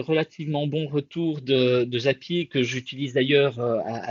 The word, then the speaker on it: Zapier: Après, euh, Zapier, euh, du coup, euh, prononcer à la belge, ça fait Zapier.